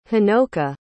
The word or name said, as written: honoka